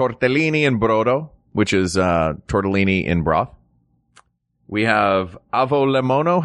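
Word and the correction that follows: avgolemono: How do you say that again? – Avogolemono.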